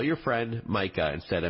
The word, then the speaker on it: Micah: So if Siri says something wrong, like pronouncing your friend Micah's name as Mika instead of Micah, then select the person in your contacts and tap Edit in the top right corner.